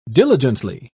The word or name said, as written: diligently